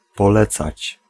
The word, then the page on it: recommander: France; /ʁə.kɔ.mɑ̃.de/